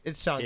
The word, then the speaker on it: Shang-Chi: It's Shang-Chi.